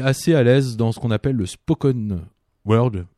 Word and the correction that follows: spoken word: on dit ça, le spoken word.